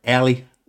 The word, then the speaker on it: Ally: But yeah, without further ado, ladies and gentlemen, boys and girls, my kinfolk, welcome to the city, the young queen, Allie Durr. What's going on, queen? – Hey, how's everybody doing? – Everybody doing well. I pronounced your name right, Allie.